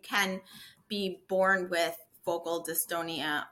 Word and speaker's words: dystonia: So, I want to ask you in your bio, I was reading that you have dystonia.